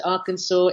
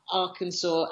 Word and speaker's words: Arkansas: And if you write out Arkansas as Arkansas, but you say it with a French accent, or with the way it would be pronounced in French, the SAS just becomes an or at the end.